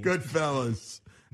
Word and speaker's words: Goodfellas: Yeah, fellows? – Sorry, Goodfellas. I'm from Jersey. – It's okay off the air, but on the air, you got to say it correctly. – Goodfellas.